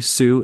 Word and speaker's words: Suh: It's uh, Stephen with a V, Suh, S U H.